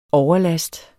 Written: overlast

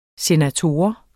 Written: Senatoren

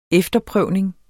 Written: efterprøvning

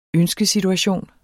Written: ønskesituation

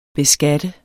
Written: beskatte

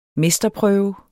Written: mesterprøve

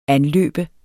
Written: anløbe

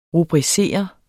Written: rubricere

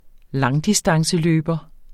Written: langdistanceløber

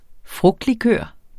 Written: frugtlikør

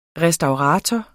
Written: restaurator

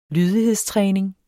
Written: lydighedstræning

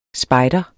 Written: spejder

